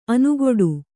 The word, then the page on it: ಅನುಗೊಡು: anugoḍu